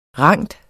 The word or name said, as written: rand